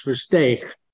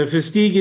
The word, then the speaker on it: Versteeg: Yeah, I never get Versteeg, as if you're clearing out your throat. Most Americans say Versteeg, and the Dutch pronunciation, my original heritage, Versteeg. So Versteeg is fine. Versteeg, if you're clearing your throat, not always an easy one.